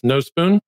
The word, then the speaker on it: Nospūn: Among the things that you usually take care of before maybe you start officially recording is making sure I got the pronunciation correct. It's Nospūn? – That's correct. – Okay. The line over the U is indeed functional.